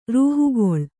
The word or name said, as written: ರೂಹುಗಳೆ